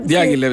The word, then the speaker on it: Diaghilev: Bien, Diaghilev, entonces, no sé cómo lo pronunciamos, Diaghilev también. – Diaghilev, sí.